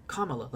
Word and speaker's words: Kamala: It's Kamala, like a Kamala. Jesus Christ. – She's like a llama, but a kuh.